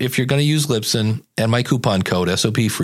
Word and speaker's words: Libsyn: Z-Man is, and I wonder if he did this on purpose, I'm leaning towards LibSync. It's actually Lisbon. Actually, I said it wrong again. That's the funny part about it. These are the two you hear, Lisbon and LibSync. It's Libsyn.